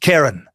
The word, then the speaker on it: Charon: It's spelled Charon, but they say it's pronounced Charon. All right, Charon.